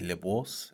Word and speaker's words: Labrosse: Like lacrosse, yes? – Yes, the correct pronunciation would be Labrosse. It's French, but I don't expect the general public to do that on a day-to-day basis.